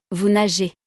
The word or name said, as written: vous nagez